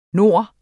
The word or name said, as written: nord